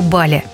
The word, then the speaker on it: Бали: Для россиян Индонезия – это прежде всего Бали. Правда, почему-то с ударением на второй слог.